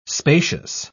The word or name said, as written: spacious